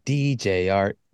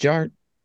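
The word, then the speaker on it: DJ Artt: And my name is Jared, aka DJ Art, with two T's for a double dose of that tink tink. The D is silent, so it's just Jart.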